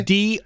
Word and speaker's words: Dothan: Dolphin? – No. Okay. My. Okay. I'll spell it because I'm sure I'm not saying it right. – Okay. – Dothan. – Dothan. – Correct. – Okay. I thought I heard an L in no.